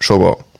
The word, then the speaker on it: Shogun: Ganz kurz, das heißt Shogun. Genau.